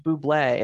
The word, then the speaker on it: Bublé: And he's just like forcing him, and he's like, it's actually pronounced Bublé.